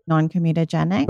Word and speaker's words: non-comedogenic: Non-comedogenic.